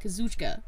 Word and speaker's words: Kazuchika: Kazuchika.